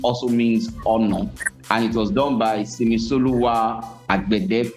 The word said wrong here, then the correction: Simisola Agbede: It's Simisola Agbede.